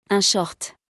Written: Un short

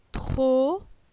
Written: Tro

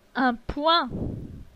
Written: Un poing

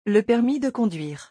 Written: le permis de conduire

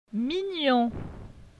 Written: Mignon

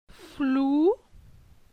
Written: Flou

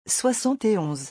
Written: 71

